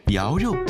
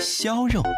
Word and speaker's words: 肴肉: 肴肉很多人念作肖肉，是江苏镇江的传统名菜，是淮扬菜系中的代表菜肴。开国大典四味冷碟，周恩来总理钦定镇江肴肉，足见肴肉的美味。